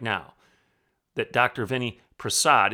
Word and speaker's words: Prasad: It's P-R-A-S-A-D, Prasad.